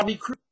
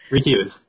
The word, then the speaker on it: recuse: So I recruit, uh, Q's, what recruits? How do you say it? Recuse.